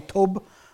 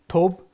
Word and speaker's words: tob: And this is another Hebrew word for you, tob. Don't really pronounce it as V, you pronounce it as a B at the end. Tob.